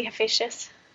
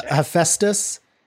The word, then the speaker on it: Hephaestus: Am I saying that correctly, Hephaestus? – There's so many pronunciations of these names that I've heard. Sure. Hephaestus is one I've heard. Okay. Hephaestus, you know. – All right. – Something like that.